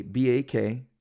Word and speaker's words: Bakshi: Now, Ralph Bakshi was a Bakshi, if I'm butchering it, but I'm saying it right.